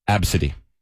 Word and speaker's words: ABCDE: Somebody at their job had to put the name down of a 6-month-old named ABCDE. – No. – They pronounced the kid's name as Absidy.